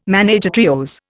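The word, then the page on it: ménage à trios: Indian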